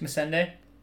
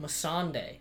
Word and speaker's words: Missandei: Missandei. Is that wrong? – Yes. – How do you pronounce it? – Missandei.